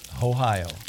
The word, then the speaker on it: OIO: And let's say, how will we pronounce this one? – O-I-O, dude. – O-O-I-O. – No, it's O-I-O. – O-I-O. Ohio. – Ohio.